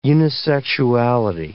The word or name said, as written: unisexuality